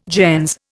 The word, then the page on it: gens: Indian